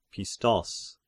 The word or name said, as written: Pistos